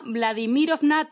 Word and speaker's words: Vladimirovna: Valentina Vladimirovna. Valentina Vladimirov. Joder. Valentina Vladimirov. ¿Será que ahí lleva tilde? Valentina Vladimirovna. No puede ser tan difícil. Valentina Vladimirovna Tereskova Nació en 1937 en el seno de una familia muy humilde.